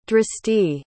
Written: dristi

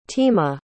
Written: tema

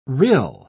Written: rill

